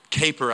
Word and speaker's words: Kuyper: I think they're going to put this quote up on the board for you, named Abraham Kuyper, I think is how you pronounce it, even though it doesn't look like that.